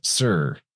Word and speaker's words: Tyre: And so their capital city is actually Sir. It's spelled T-Y-R-E. Most people will say it's Tyre, but they pronounce that as Sir.